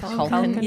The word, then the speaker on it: Culkin: Culkin, yes.